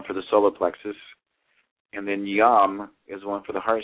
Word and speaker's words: yam: which is yam, Y-A-M, and then you have the throat chakra, which is ham, H-A-M.